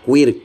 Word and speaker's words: queer: Queer con C, queer, porque es como lo pronunciamos nosotros, ni siquiera es el origen este, de la palabra.